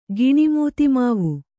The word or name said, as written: ಗಿಣಿಮೂತಿಮಾವು